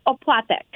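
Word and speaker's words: opłatek: Can I ask what Is it Oplatek? Did I pronounce that right? – Oplatek.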